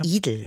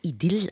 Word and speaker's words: Idil: Er sagt Idel. Ja, Idel. Ja, aber das ist doch, wie denn? Der wird Idil ausgesprochen, also schön. Idil. Ne, und warum machst du das? Oh, wozu? Idil. Idyll. Idyll. Perfekt, Idyll. super.